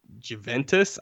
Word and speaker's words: Juventus: He recently transferred clubs from Real Madrid to Juventus.